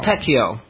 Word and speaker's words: Pacquiao: I mispronounced it again, I think, didn't I? – Pacquiao. – No, Pacquiao. Pacquiao. – Yep.